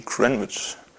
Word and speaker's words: Greenwich: Da habe ich eben den Nullmeridian, der hier ist, was in Greenwich, genau, irgendwie, ich war in London vor einem Jahr oder so und die haben gemeint, das heißt nicht Greenwich, sondern irgendwie Greenwich.